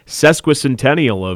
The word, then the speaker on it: sesquicentennial: And a neat topic I know we heard a little bit about earlier this month, that is the sesquicentennial of UW-Oshkosh here in 2021.